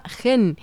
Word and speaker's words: khene: Llega desde Laos y está hecha con un instrumento pues muy especial, se llama gen. Si queréis buscarlo para ver cómo es, se escribe K-H-E-N-E, de Laos.